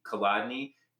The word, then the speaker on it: Kolodney: But I'm going to change my domain name, I don't know when, to just my name, Casey, C-A-Y-C-E, Kolodny, K-O-L-O-D-N-E-Y.